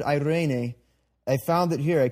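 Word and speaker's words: eirene (εἰρήνη): Your third and final Greek word this morning is the Greek word for peace, which is pronounced eirene. Let me hear it. Eirene.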